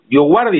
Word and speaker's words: Dioguardi: ¿Lo dije bien? ¿Pasta tu apellido? ¿Dioguardi está bien? O, Perfecto, perfecto. Así, Dioguardi. Exacto.